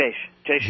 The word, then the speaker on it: Jaish: Jaish.